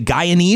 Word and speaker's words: Guyanese: Do you say Guyanese? Is that how you pronounce it? You're from Guyana, is that Guyanese.